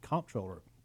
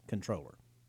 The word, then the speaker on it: controller: During the campaign commercials, you'll see I said comptroller, but technically it is pronounced controller.